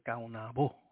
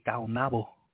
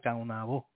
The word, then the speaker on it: Caonabo: C-A-O. N-A-B-O. And people, I always pronounce it Kaunabo. I put the accent on the last O. Some people say Kaunabo. Kauna, accent on the A. For some reason, I like Kaunabo. So it's C-A-O-N, accent on the A, or B-O, accent on the O. I don't know, I just like that sound, Kaunabo.